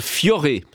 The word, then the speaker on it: Fiore: le chanteur tessinois Valentino Vivace avec son dernier single Fiore, ça se prononce comme ça, sur RTS Première.